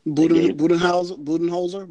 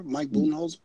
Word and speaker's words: Budenholzer: Budenholzer, Mike Budenholzer.